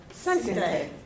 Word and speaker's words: Sinte: Sente. – Spell it. – S-I-N-T-E. – Sente.